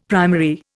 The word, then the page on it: primary: Indian